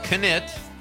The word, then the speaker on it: Knit: Craig Anthony Knit, I'm saying that right, the K is not silent,